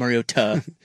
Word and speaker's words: Mariota: It's Mariota. Mariota.